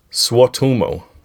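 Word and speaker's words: Suotamo: Eunice Swatumo. – Swatumo. – Swatumo. Swatumo. – Swatumo. – Swatumo. – Actually, Swatumo. In Finnish, you always stress the first syllable of every word, no matter what, which is pretty cool.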